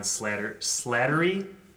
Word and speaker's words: Slattery: He was in there as well, and of course, Mark Ruffalo as well, and add-ons, Liev Schreiber, Stanley Tucci, and John Slattery, I think is how you pronounce his name, from Mad Men as well.